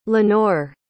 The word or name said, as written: lenore